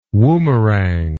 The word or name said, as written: woomerang